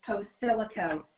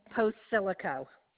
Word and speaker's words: Posilico: My first name is Colette, C-O-L-E-T-T-E. And my last name is Posilico, P as in Paul, O, S as in Sam, I-L-I-C-O. – What's that? Okay, just say your name for me. I'm sorry. – Colette Posilico.